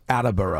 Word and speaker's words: Attleboro: They do not pronounce the L-E in Attleboro. It's Attleboro. – Attleboro. – Attleboro. Attleboro. It is Attleboro.